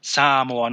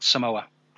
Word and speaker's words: Samoa: We like to say Samoa. When we talk about Samoa, we kind of accentuate the first vowel, the first A. That's how it's normally pronounced. It's Samoa, not Samoa.